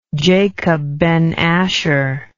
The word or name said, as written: Jacob ben Asher